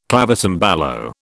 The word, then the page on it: clavicembalo: British English